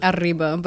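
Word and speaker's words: Arriba: Is that how you pronounce it? – Ariba, but well, Ariba, yeah. – I wasn't going to go for it because I know I can't roll my R's that well.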